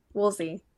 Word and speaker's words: Woolsey: Rowan, how do I say your last name again? – Woolsey. – Okay. It'd been like, 8 plus years. So I couldn't remember if it was like it's Woolsey.